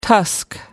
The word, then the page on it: tusk: American English